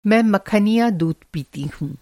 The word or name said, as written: मखनिया